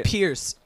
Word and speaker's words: Piers: And John Malkovich's character is just Piers, right? – Pierce. – Yeah. – Pierce. Yeah. – Yeah. But spelled like Piers.